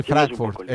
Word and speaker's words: Frankfurt: Sí, Frankfurt.